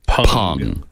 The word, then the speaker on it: Peng: And even though it is spelled P-E-N-G, the first lady's name is pronounced Peng. – Peng. – P-U-N-G. Yeah.